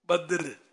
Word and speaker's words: بدر: درست اس کا تلفظ ہونا چاہیے، بدر بدر بدر، نو بدر، واٹ از بدر،